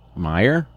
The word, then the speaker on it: Meijer: Meijer? – Meijer.